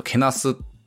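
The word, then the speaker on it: 貶す: 彼らは大学時代私の体型をいじったり、他の女性の見た目をこれなんて読むやろ、他の女性の見た目をふんしたり、貝に貧乏の棒と書いてけなしたりか、あ、けなしたりって読むや、これ貶めるっていうね読み方もするんですけど、けなすという使い方もあるんですね。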